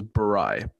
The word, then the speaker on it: Borai: My last name is Barai, but Ethan has said it incorrectly at least 100 times this week.